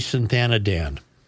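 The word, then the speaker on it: Sathanandan: Shivanti Sathanadan.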